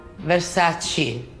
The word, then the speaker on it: Versace: Which Italian word do you wish English speakers would stop saying incorrectly? – Versace. Is Versace? Versace.